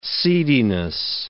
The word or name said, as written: seediness